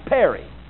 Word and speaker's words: peri: Now, the preposition is pronounced peri. The reason I pronounce it for you is because you'll recognize we get our concept of perimeter from it.